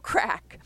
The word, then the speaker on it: craic: the wonderful craic. That's spelled C-R-A-I-C, Gaelic for enjoyable social activity.